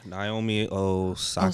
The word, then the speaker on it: Osaka: Naomi Osaka. – Osaka. Oh, Osaka.